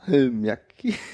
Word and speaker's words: Salmiakki: Und du musst auf der ersten Silbe betonen. Sa, Salmiakki.